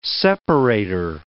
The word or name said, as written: separator